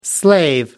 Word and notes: slave: American English

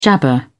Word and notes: jabber: British English

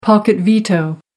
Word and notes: pocket-veto: American English

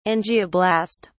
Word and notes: angioblast: British English

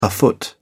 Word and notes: afoot: English; British English

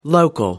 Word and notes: local: American English

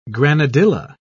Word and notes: granadilla: British English

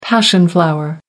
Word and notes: passion flower: American English

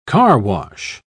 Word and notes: carwash: British English

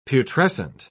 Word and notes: putrescent: British English

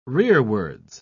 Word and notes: rearwards: British English

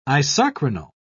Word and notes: isochronal: British English

